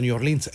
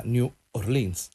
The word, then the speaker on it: New Orleans: Il nome va da sé che noi lo pronunciamo all'inglese o all'americana, New Orleans